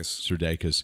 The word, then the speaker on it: Sudeikis: Sudeikis.